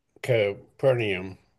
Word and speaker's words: Capernaum: After a brief visit to Capernaum, I think I said that right. – Yeah, you did.